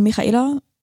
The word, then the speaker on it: Michaela: Michaela, äh, das H in Michaela wird wie das CH in Ach ausgesprochen.